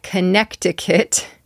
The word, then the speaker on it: Connecticut: It looks like Connecticut, but there's also a silent C in the middle. So Connecticut. That T in the center becomes a D.